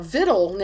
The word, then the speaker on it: victual: Interestingly, vittle now, V-I-T-T-L-E, not victual, is considered an alternate spelling now.